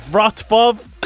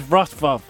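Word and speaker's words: Wrocław: It's Vratvav. – It's Rocklaw. – It's not Rocklaw. – It's spelt Roclaw. Rocklaw. Yes. Rocklaw. – Show a bit of culture, would you? It's pronounced Vratvav.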